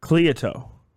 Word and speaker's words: Cleito: It was then that he fell in love with, I'm trying to say this correctly, Cleito. – I believe that's right. That's as close as we, as non-ancient Greeks, can get it.